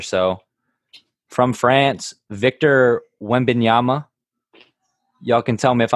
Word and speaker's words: Wembanyama: I think it's Wembanyama.